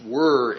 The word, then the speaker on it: vir: Both of these words come from the Latin word virtus, V-I-R-T-U-S, and it's related to manliness because vir, V-I-R, or it's really pronounced were in Latin because Latin V's are pronounced like English W's.